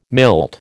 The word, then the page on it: milt: American English